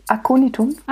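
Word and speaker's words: Aconitum: Da steht jetzt in Klammern, dass das Okonitum heißt. Habe ich das richtig ausgesprochen? – Akonitum. – Ah, ja, genau.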